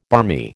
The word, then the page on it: barmy: American English